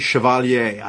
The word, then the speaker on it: Chevalier: And I pronounce it the French way, Chevalier. I don't know if it's Chevalier, like the way, you know, but think about Chevrolet. That's the way I'm going with it.